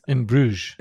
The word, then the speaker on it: Bruges: really liked that I can never pronounce is In Bruges. – It's In Bruges.